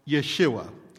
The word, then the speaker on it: Yeshua: In Hebrew, Jesus' name would be pronounced Yeshua, meaning Savior or God saves.